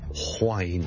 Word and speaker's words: whine: 其实如果你学某一啲 accent 咧，系的确咧系会读 whine 咁样嘅。例如愛爾蘭的英文很多時候都會聽到 Wine W-I-N-E 和 W-H-I-N-E 發音是不同的，聽著第一個是 Wine Wine Wine Wine Wine，第二個是 Wine Wine Wine Wine Wine，聽下你的感覺去當它好像 H 音之前跟著先加個 Wine Wine and Wine，那就不是錯不錯的問題，而是不同地方的口音。